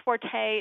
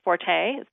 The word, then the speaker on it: forte: And I think forte is one of those words, because I would bet that 90% of people think it's pronounced forte. That's my forte. – Or that was the way it was supposed to be. – Right, but you're right. Technically, the correct pronunciation is fort.